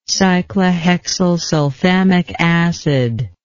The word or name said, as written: cyclohexylsulfamic acid